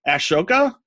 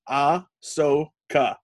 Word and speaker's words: Ahsoka: How do you say this? Ashoka? No, nope, it's Ahsoka is how you say it.